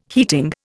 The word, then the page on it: heating: Indian